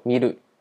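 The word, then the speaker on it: 見る: 那二类动词就是最简单，我们举的例子呢是看这个动词，看这个动词是一个典型的二类动词，它念作 miru。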